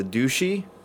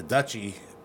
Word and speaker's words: Douchie: It's spelled D-O-U-C-H-I-E. Would that Past the Douchey or Past the Dutchie? – It's got to be Past the Dutchie on the left-hand side, but there's no left-hand side.